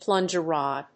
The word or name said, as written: plunger rod